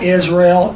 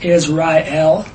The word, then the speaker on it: Israel: And so it's not Israel, not two syllables, it's Israel, three syllables.